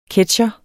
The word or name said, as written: ketsjer